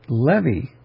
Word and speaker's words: Levi: Just occurred to me today, we don't have to make the E a long E just because the accent is on the E. It could be Levi. It doesn't have to be Levi.